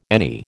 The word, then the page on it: any: American English